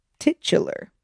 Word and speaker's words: titular: Titular. Am I saying that right? – Yeah. – Really? – Yeah.